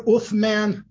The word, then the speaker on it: Uthman: If the word Uthman sounds a little familiar, it's the Arabic pronunciation of what we better know in Turkish as Ottoman.